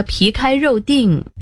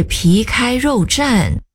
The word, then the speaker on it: 皮开肉绽: 唉，也不至于被打得皮开肉定。 – 是皮开肉绽。站字左边是绞丝旁，右边是安定的定，得读作站。